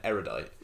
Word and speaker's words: erudite: I spent a full day trying to figure out in my head how you could come to the conclusion that erudite is pronounced anything other than erudite. – Mm. – And I was like, maybe, just maybe, it's like the long U as in usual.